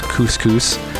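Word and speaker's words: Quscus: That was music from Antanasia from their album, which I think is pronounced Couscous. It's Q-U-S-C-U-S, and that was Gynoid.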